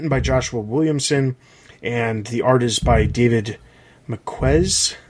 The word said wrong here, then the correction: Marquez: I'm probably pronouncing that wrong. He comes from Marvel, has been working on Marvel stuff for quite some time. – Marquez.